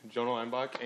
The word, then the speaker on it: Jonah Leinbach: But so my name is Jonah Leinbach, and that is how you pronounce it.